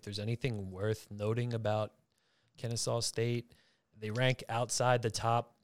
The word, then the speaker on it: Kennesaw State: Next game, we got Xavier taking on, what is it, Kennesaw State? Did I say that right? – Yep, Kennesaw State.